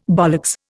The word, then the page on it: bollocks: Indian